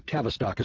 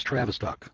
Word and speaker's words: Tavistock: It says, you're mispronouncing Tavistock as Travistock. There's no R in the word.